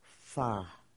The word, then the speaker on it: pho: And my favorite is pho.